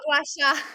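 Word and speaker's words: gua sha: Comment on prononce? Gacha? – Gacha. – Ouais, voilà. Gacha.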